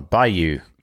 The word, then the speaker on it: Bayou: Created in celebration of the spirit of Mardi Gras and the pride of Louisiana, the rare Bayo. I'm going to show this up. – How do you spell it? – Yeah, B-A-B-A-Y-O, like the Louisiana Bayo, right? – Oh, Bayou. – Bayou.